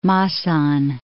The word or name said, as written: Masan